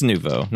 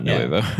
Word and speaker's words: Nouveau: Yeah, it's Nouveau. I'm sorry. I'm looking at it now. It's Nouveau, not Nuevo.